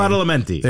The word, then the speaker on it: Badalamenti: Angelo Badalamenti, I could be butchering his last name. – Badalamenti.